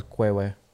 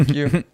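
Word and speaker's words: queue: I always thought it was Kwewe or something like Kwewe. That. – Kwewe. No, Q.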